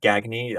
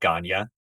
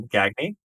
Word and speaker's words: Gagne: gagne i think you pronounce it the american way not like ganya stefan gagne um, I think he's the guy who set up this website called Improv Fanfic, and uh-huh.